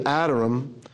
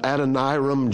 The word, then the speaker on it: Adoniram: And I, well, in Hebrew, it's actually Adoniram. She said, well, I've heard people calling it Adoniram. Yeah, Adoniram. Well, no, that's not even close to right. That's some kind of British pronunciation.